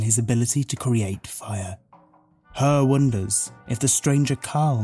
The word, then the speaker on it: Hur: anyway, this character, Old Mother, and another cave woman who's called Her, which is spelled H-U-R, but is just Her.